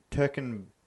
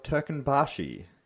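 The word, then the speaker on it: Turkmenbashi: Or the following year, Niyazov declared himself Turkanbashi. That one's a hard one because it's got a couple of the umlauts in there and a few other things, but I think that's how it's pronounced.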